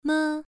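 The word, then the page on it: me: tone 1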